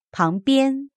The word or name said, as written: pang2bian1